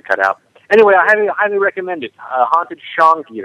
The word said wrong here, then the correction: Changi: It's Changi, and write a letter if you know how to pronounce the word, right? – Yes.